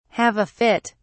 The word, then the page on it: have a fit: British English